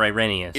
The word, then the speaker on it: Irenaeus: So this stuff about Irenaeus that we're going to, is it Irenaeus or Irenaeus? – Irenaeus is typically how it's pronounced in English.